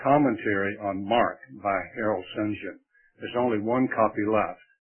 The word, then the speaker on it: St. John: His last name is spelled S-T-J-O-H-N. Most people call it St. John, but it really is St. John.